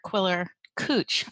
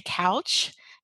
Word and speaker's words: Quiller-Couch: Arthur Quiller Cooch. I'd like to say Couch and that's how it's spelled. But um, apparently, his name is Quiller Cooch.